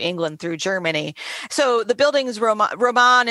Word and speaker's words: Bildungsroman: Yeah, and the pronunciation is pretty good. I mean, it's a German word, Bildungsroman,